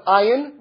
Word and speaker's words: Ayin: remember it's spelled Ayin, which is a silent letter in Hebrew, Ayin Sadi, and we have to turn the Ayin into an Aleph, right?